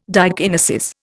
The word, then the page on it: diakinesis: Indian